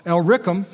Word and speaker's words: Illyricum: Illyricum, I put the emphasis on the wrong syllable, Illyricum, and he said, I have fully preached the gospel of Christ.